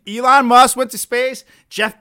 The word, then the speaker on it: Elon: Yeah, it's Elon. – It's spelled what you think it was? – Ellen. E-L-E-L-O-N. – It's not Ellen. Like Ellen DeGeneres? – Yeah. I thought it was pronounced Ellen. – No.